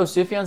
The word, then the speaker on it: Sufjan: Call Me By Your Name, the music in this movie was, how do you say the guy's name? Sufjan Stevens? – Sufjan? Oh, Sufjan Stevens? – Yeah.